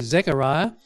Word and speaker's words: Zechariah: And as it happened, our friend Zechariah, to whom we're introduced in verse 5, now you might notice some of your Bibles say Zechariah, which is a Hebrew pronunciation, and some of them might say Zacharias. And that is the Greek way of saying it, because it's been translated across.